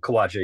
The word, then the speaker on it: Khwaja: It actually is Aamar and Khwaja.